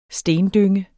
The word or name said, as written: stendynge